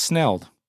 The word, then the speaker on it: snelled: basically schnelled their hooks. With schnelled? Did you just pronounce that schnelled? Was schnelled? – Is that not how it is? It's just snelled.